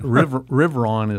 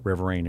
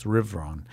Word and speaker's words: Riverain: Riveron is the correct pronunciation. We call it Riverain. Everybody calls it Riverain, but it's Riveron.